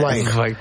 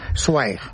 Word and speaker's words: Zweig: Sí, pero desde que el bardo nos ha llevado por el camino bien, ahora hay que decir Zweig. – Zweig. – Zweig. Esteban Zweig. – Es así como se pronuncia en alemán.